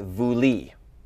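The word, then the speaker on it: vouli (βουλή): And this word in modern pronunciation is pronounced vouli.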